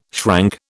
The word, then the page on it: shrank: British English